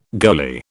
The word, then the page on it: goalie: British English